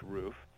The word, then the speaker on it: RUF: And then if you have, like, the itch for something really fun, you would go with a roof, which is RUF. Some people pronounce it rough, but it's actually pronounced roof.